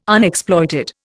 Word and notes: Unexploited: Indian

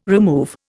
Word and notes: remove: Indian

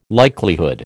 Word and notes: likelihood: American English

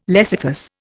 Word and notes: lecythus: Indian

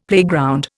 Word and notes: playground: Indian

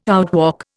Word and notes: outwalk: Indian

